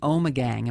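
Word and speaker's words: Ommegang: Brewery Omegang. Am I pronouncing that right? – You are correct.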